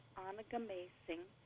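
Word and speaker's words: Onigamiising: Well, it's the release date was just Tuesday, and so it's really brand new out, and it's pronounced Onagum Acing, Seasons of an Ojibwe Year. And Onagum Acing is the Ojibwe, it's a name for Duluth, and it translates to mean the place of the small portage.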